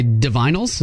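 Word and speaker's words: Divinyls: I Touch Myself by Divinals.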